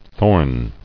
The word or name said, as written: Thornless